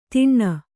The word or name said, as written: ತಿಣ್ಣ